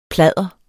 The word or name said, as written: pladder